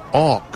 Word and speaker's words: Och: His last name is spelled O-C-H. Josh Auk.